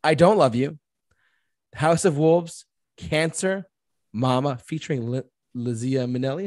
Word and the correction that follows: Liza Minnelli: Is that how you say it? – Liza Minnelli. Yep. – Liza Minnelli. Thank you.